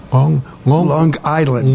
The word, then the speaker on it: Long Island: You have to pronounce it correctly. It's Long Island. – Long Island. – Long Island? – No, yet it's hard G. No, hard G. Long Island.